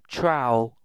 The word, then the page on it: trowel: British English